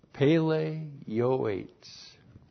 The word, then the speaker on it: Pele Yoetz: In the Hebrew, we have Pele Yoetz. Oh, well, no wonder. Pele Yoetz. That's P-E-L-E, Aleph, and then Y, long O, I-N, E-T-S, Pele Yoetz. So, there's two words here. And the first word is Pele, P-E-L-E, Pele.